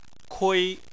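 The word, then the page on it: kui: tone 1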